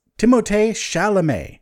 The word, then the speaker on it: Timothée Chalamet: I just saved it so I can remember how to pronounce his name, because I've been saying it wrong this whole time. Apparently it's Timothée Chalamet.